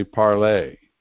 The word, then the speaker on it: Parler: And it's probably going to continue to be called Parler, but it's actually Parler. because it is also a French word. Because parlor in English is spelled with an O-R, not an E-R. So P-A-R-L-E-R is the French word parler, which means to speak.